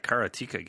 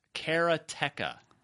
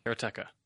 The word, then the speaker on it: Karateka: straight from Mechner, it's Karateka. Karateka. All right. Should have went with Karateka.